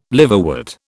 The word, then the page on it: liverwort: British English